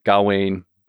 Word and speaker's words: Gawain: And Gawain. It's pronounced both ways, but Gawain and the Green Knight in, it's more difficult Middle English.